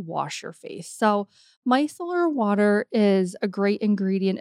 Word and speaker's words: Micellar: Micellar. – Yep. – Did I say it right? – Yep. Micellar.